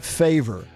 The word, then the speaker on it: Favre: So they mispronounce Brett Favre's name at the podium. The commissioner says Brett Favre. But Chris Berman, having done his homework as the analyst on ESPN, knows the correct pronunciation and turns it around and smooths it over and says they get a favor by drafting Brett Favre out of Southern Mississippi.